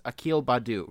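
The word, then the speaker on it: Akil Baddoo: So James was mad because it's Akil Badu, right? That's how you say his name.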